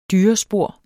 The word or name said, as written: DYRESPOR